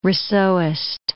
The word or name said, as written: Rousseauist